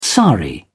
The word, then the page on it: sari: British English